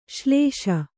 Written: ಶ್ಲೇಷೆ

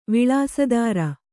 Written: ವಿಳಾಸದಾರ

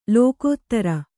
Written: ಲೋಕೋತ್ತರ

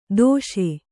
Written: ದಾಶ

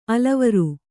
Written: ಅಲವರು

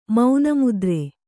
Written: ಮೌನಮುದ್ರೆ

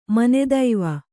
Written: ಮನೆದೈವ

